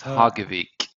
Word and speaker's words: Hagevik: It's Hagavik.